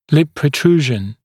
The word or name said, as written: lip protrusion